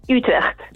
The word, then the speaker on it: Utrecht: Tu adesso vivi ancora lì, vivi a Utrecht, che tu in realtà pronunci con la dizione giusta, che è? – Utrecht.